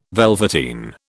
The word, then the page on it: velveteen: British English